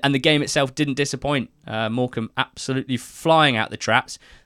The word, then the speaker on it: Morecambe: Tom, who is our Morecambe fan from Peru on the marvellous NTT20 squad, called this the Silent B Derby. Both Wickham and Morecambe having a rogue B in there.